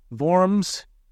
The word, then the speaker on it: Worms: Worms, pronounced Worms in the German language, was a city in Germany where Luther was put on trial for his teachings.